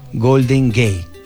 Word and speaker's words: Golden Gate: Golden Gate. – O sea, gate se escribe, pero es gate. – Sí, gate. Totalmente cierto.